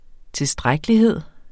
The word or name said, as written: tilstrækkelighed